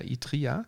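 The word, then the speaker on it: Itriya: Das Wort Itrya würde ich es jetzt aussprechen. Also geschrieben wird es I-T-R-Y-I-A, Itria. Utria, Itria, das in der vereinfachten Form Tri ins Sizilianische übernommen wurde, bedeutet nichts anderes als Pasta-Stränge mit Loch.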